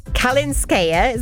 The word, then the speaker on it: Kalinskaya: including French Open star Anna Kalinskaya, is that ever said that right? – Sounds good to me.